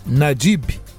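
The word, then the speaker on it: Nayib: E a preocupação faz sentido, porque o, o, o Najib, ou Najib Bukele, né, escreve-se N-A-Y-I-B. E o Y, curiosamente em espanhol, ele acaba dando essa pronúncia de G né? Nadib, né?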